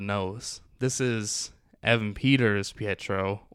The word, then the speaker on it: Pietro: Pedro. Did I say it wrong? – There's just like, you almost say Pedro. There's a like Pietro.